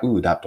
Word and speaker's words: OODA: で最初に早速なんですが、ウーダループです。皆さんはウーダループお聞きになったことはあるでしょうかウーダループとは、英語で OODA と書きて、えー、読み方がウーダと読みます。